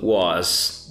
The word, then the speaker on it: was: W-E-R-E ok? Uh, e aí você tem também a conjugação was, W-A-S, com esse sonzinho de Z quando fala, e não é o as, ok? É som de O, W-O-S, I say was, W-A-S, I pronounce was.